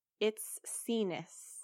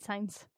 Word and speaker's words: Itsines: Okay, so it's spelled Itsines. It's pronounced It's Sinus. – No way. It is. I've been saying it signs. It's Sinus. – I think I was saying like It's Knees.